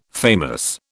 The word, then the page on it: famous: British English